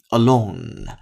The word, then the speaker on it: alone: 注意这个单词结尾的发音 alone。您一定要在结尾有一个 n 音的鼻腔共鸣，嗯，alone，alone 这样的声音才正确。